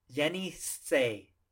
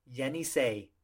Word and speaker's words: Yenisei: So it's going to be Yenisei. Yenisei. And that's the fifth largest river. That last one, the pronunciation is a little bit harder, but you know, Yenisei, penny, hay. So boom.